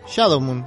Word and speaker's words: Shadow Moon: Sí, pero ¿cómo se pronuncia bien en inglés? Shadow Moon.